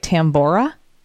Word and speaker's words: Tambora: am I saying this right, Tambora? – Oh, yeah.